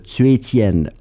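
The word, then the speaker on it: Cui Jian: Je vous présente Cui Tian, C-U-I espace J-I-A-N, Cui Tian, ça s'écrit comme ça se prononce.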